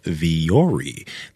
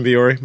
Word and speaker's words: Vuori: And the shorts that we're always wearing are from a company called Viori. That's spelled V-U-O-R-I. But Viori has amazing clothes for the gym that we wear, but also outside the gym.